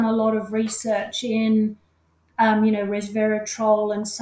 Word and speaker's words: resveratrol: Resveratrol, thank you very much.